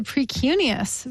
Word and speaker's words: precuneus: Yes, the precuneus.